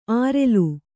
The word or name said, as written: ಆರೆಲು